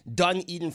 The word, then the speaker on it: Dunedin: Apparently, I said it wrong the first time. Dunedin,